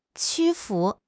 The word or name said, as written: 屈服